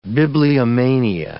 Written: bibliomania

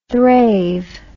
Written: thrave

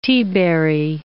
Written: teaberry

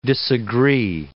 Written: disagree

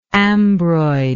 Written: ambroid

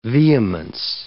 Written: vehemence

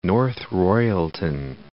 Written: North Royalton

